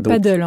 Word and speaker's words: padel: Je parlais du padel tout à l'heure, qui est une nouvelle discipline. En quelques mois, le padel, les règles du padel, etc., ont évolué. C'est pas le padel, hein, c'est le padel.